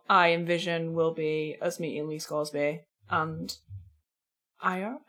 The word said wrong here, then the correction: Iorek: Ira Iorek? Iorek.